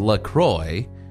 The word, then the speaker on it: LaCroix: Officially, it's pronounced LaCroix. It also rhymes with enjoy.